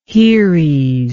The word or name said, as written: haeres